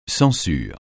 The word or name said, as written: censure